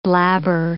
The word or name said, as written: blabber